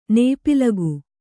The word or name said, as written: ನೇಪಿಲಗು